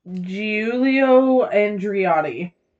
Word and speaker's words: Giulio Andreotti: After the war, he studied law and was briefly a deputy to the future Italian Prime Minister, Giulio Andretti. I am so sorry. I'm going to butcher the hell out of that. I'm Giulio Andreotti.